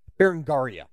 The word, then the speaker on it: Berengaria: But then Richard and Philip kind of fell out over the issue of Richard's marriage, as Richard had decided to marry Berengaria, that's how you'd say her name, Berengaria of Navarre, breaking off his long-standing betrothal to Philip's half-sister, Alice, who was his former wife.